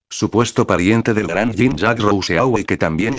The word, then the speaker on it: Rousseau: Rouxot se pronuncia igual que Rousseau.